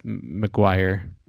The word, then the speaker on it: McGuire: Rory is played by Joshua McGreer. – I think it's McGuire.